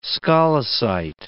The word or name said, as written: scolecite